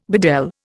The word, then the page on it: bedel: Indian